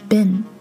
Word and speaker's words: been: 我们讲到了这个单词虽然有两个 e 连在一起，就像蜜蜂的那个单词一样，b e e b，但是 ben b e e n 它的标准发音是 ben，是一个短音。